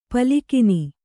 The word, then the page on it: ಪಾಲಘ್ನ: pālaghna